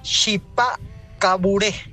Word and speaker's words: Chipá Caburé: Chipá Caburé. Así se pronuncia.